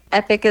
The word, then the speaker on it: Eppich: So Epic is our last name, but it's spelled kind of funny.